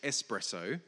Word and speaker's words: espresso: And eventually I learned indeed that it was pronounced espresso, and coffee lovers everywhere rejoiced.